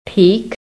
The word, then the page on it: peak: American English